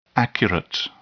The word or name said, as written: accurate